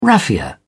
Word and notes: raffia: British English

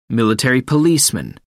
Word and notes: military policeman: American English

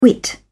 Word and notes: whit: British English

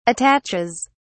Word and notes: attaches: British English

